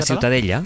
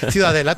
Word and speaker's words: Ciutadella: ¿cómo se pronuncia? – La Ciutadella. – Ciutadella. Bueno, pues Ciudadela también en castellano.